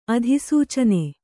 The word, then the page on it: ಅಧಿಸೂಚನೆ: adhisūcane